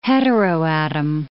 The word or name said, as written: heteroatom